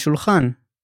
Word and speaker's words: שולחן: We follow it with shulchan, meaning a table. Let's break down this word by syllable. Shul-chan. Shulchan.